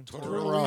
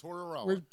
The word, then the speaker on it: Tortorella: Tortorella. – I've never been good at it. – Tortorella.